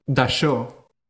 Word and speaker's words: Dachau: Se llama Dachau. Se escribe D-A-C-H-A-U.